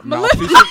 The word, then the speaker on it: Maleficent: Speaking of Magical Girls, I got to see Maleficent. Am I pronouncing that one right? – Maleficent.